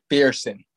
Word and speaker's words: McPherson: McPherson. It's McPherson.